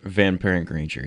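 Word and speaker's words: Van Pran-Granger: I got to say it right again. Cedric Van Pran Granger... – Van Pran Granger, yeah.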